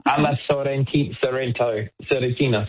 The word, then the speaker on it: alla Sorrentina: Alla Sorrentina. It's like it's gnocchi, but they don't use flour. I don't know what they do, but it's the best gnocchi in the world. – Wow. – Alla Sorrentina.